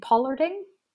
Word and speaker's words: pollarding: Coppicing and pollarding. – Perfect pronunciations.